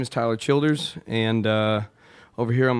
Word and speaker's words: Tyler Childers: And in the meantime, we're going to talk to our friend Tyler Childress. Is that, did I say it right? – Tyler Childers. You can Childers.